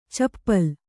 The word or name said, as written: ಚಪಲೆ